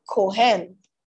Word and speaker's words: kohen: The word priest is from the Hebrew word kohen. It is spelled C-U-H-E-N or K-E-M-K-O-H-E-N, right? Can somebody put it there? C-U-H-E-N or K-O-H-E-N, Kohen.